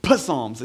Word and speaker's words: Psalm: It's the first psalm, or pronounced psalm, if you look at it, it's a P in front of it. I don't know why we don't call it that, but Psalms. It's just Psalms.